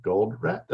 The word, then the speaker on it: Goldratt: The analogy that I like to use is the book, The Goal by L-U-M Goldratt. Did I say that right? I can't pronounce it. Yeah, that's good.